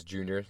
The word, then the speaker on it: Junior: So my Instagram name is Junior, that's spelled out J-U-N-I-O-R underscore powerhouse247.